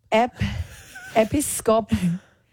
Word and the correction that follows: Episcopalian: Leanne. – Okay. – Episcopalian. – This is the actual pronunciation. – Episcopalian.